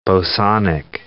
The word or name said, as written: bosonic